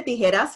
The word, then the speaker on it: Tijeras: Now, I've been dying to say Tijeras because that's how you say it.